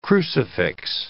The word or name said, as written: crucifix